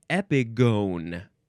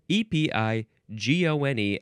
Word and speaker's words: epigone: Okay, next is epigone. No, it's epigone. Epigone. E P I G O N E. And I would want to pronounce this epigon because that's how it's spelled, but no, it's epigone.